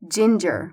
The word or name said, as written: ginger